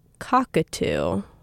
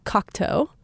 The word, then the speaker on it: Cocteau: but the entire show I was pronouncing it Cockatoo. Which is really wrong. It's Cocteau. I was pronouncing it like the bird.